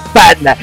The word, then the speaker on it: PXNDX: En vez de Panda como tal, así textual, era PXNDX, que pues bueno, se interpretaba como la X, como la A, por tanto, se escribía, pero se pronunciaba Panda.